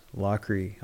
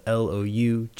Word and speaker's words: Loughrie: Wes Loughrie. Wes spelled WS, my last name is spelled Loughrie. I'm not hard to find. I think I'm like the only Wes Loughrie out there, so it's a pretty unique name.